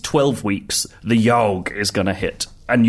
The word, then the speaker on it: YAHWG: The Yorg is a very strange game. It is. – It looks like a typo. – It does look like a typo. It's spelled YAHWG, and essentially, it's a social choose-your-own-adventure game.